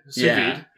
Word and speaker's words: sous vide: In a sous vide. Am I saying that right? – Sous vide.